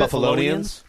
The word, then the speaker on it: Buffalonians: Buffalonians.